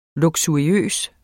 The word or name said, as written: luksuriøs